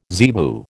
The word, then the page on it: zebu: American English